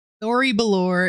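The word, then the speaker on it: Thori'belore: So Thori Belor is, and I probably said that completely wrong. 4 mana, 4/4 elemental. Wait, for warrior. – On. I think you said it correctly, but also Thori Belori just sounds like a nickname you'd give someone in middle school.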